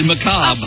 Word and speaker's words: macabre: It's actually macabre. – Of course it's macabre.